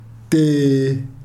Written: tii

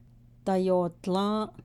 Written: dayoodlą́ą́’

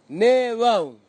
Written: nevau

